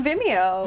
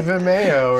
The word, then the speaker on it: Vimeo: On Vimeo. – Vimeo. – Yeah, true. – Yes. I always want to say Vimeo or something.